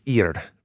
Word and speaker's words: Ir: There's a hyperlink there of Cain building the Ir, which is Hebrew for city, through that kind of like... – Yeah, good pronunciation.